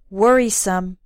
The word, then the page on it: worrisome: American English